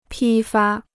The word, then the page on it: 批发: pī fā